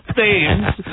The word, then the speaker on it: Thames: Pronounce it correctly. The Thames.